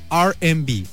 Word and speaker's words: R&B: Mujer estadounidense que tuvo impacto en el mundo del jazz, el soul y el R&B, que se pronuncia R&B, casi como los Airbnb, pero no, son diferentes.